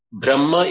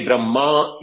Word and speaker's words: Brahma: When you say Brahma with a long akara, a in the end, it pertains to the Trimurti, Brahma, Vishnu, Shiva. But when we say Brahma with a short a, it is indicating the Brahman, which is that, the Napumsakalinga Brahman, and when in the Prathama Epa Vibhakti, it is known as Brahma.